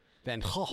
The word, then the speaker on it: Van Gogh: It just literally sound like you're cocking up a loogie. – Van Gogh. – Yeah. – Okay. – Because he was Dutch, and that's how it's pronounced in Dutch.